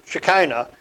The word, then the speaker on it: Shekinah: The title of this is Shekinah. Let me spell that for you. S-H-E-K-I-N-A-H. Shekinah. It also can be spelled with a C-H instead of the K, but it has the same sound.